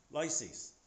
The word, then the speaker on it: Lysias: Lysias, that's how you pronounce it.